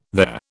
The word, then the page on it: their: British English